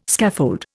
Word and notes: scaffold: Indian

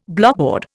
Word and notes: blockboard: Indian

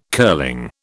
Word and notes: curling: British English

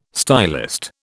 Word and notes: stylist: British English

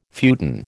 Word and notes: futon: American English

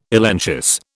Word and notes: elenchus: British English

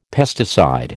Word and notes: pesticide: American English

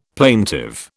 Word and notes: plaintive: British English

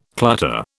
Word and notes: clutter: British English

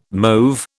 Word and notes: mauve: British English

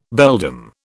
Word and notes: beldam: British English

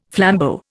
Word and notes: flambeau: Indian

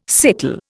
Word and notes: sickle: Indian

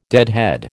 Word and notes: deadhead: American English

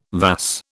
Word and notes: vas: British English

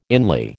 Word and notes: inly: American English

